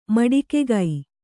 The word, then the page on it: ಮಟ್ಟಿಕಾಗೆ: kannada